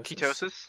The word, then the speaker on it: ketosis: After a certain amount of times, I want to say it's 3 to 4 days, you go into the state of, how do you say it? Kenosis? – Ketosis. – Ketosis.